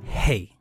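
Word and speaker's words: hey (ה): The only change in the spelling, the Hebrew spelling for both names, is adding the letter H, which is pronounced hey. Hey. That's the Hebrew word for what we would call H.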